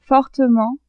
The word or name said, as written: fortement